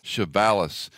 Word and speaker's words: Chavalas: Chavalas.